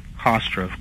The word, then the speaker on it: Hofstra: Was it Hofstra University? I'm pronouncing it wrong. – Hofstra, correct.